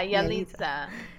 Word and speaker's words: Yalitza: Is that how you pronounce it? – Yeah, Yalitza.